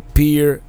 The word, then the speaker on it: Pierre: Now today we are in Pierre, as I've been reliably informed is pronounced, is the capital of North Dakota.